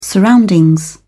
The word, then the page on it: surroundings: British English